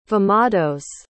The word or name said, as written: vemados